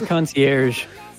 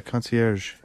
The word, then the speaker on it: concierge: I always said the G. – Concierge, yeah. – Yeah, I bet I'm supposed to say J. Concierge.